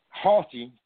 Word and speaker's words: haughty: And they were huggity, I'm trying to pronounce the word right, huggity and haughty, committed, haughty, thank you, and they were haughty and committed, committed abominations before me.